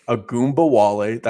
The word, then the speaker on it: Ogunbowale: Secondly, and here we go with the name game, Dare Ogunbowale. – Ogunbowale. – That's how you say it.